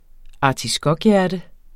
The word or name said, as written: artiskokhjerte